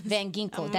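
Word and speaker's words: Van Ginkel: Andrew Van Ginkle, is that how you say his last name? I don't want to butcher it, my friend. – I remember the last time. – Van Ginkle, that's what it is.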